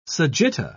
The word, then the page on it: sagitta: British English